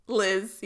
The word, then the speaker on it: Leezh: Rahash and Leej. L-E-E-Z-H. – Liz, yeah. – Okay, good. – Liz. That's how they pronounce Liz.